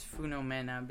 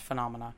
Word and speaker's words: Funomena: I believe it's pronounced Phenomena, which makes sense.